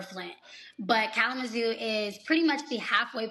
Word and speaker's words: Kalamazoo: You said Kalamazoo? – Kalamazoo, yes. – Spell it. – K-A-L-A-M-A-Z-O-O.